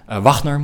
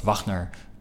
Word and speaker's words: Wagner: Wagner moet ik zeggen. Wagner is de componist. Wagner is de Shell voorman. – Kleine verschillen. – Klein verschil inderdaad. Het wordt vaak als Wagner uitgesproken omdat die naam zo maar heet Wagner. Heette Wagner.